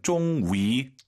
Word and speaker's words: 终于: 자, YU 발음, 쫑위, 다 알아들어요. 근데 입술을 동그랗게 말아서, 쫑위.